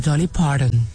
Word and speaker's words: Dolly Parton: ¿cómo se dice Dolly Parton? – No, señor, Dolly Parton.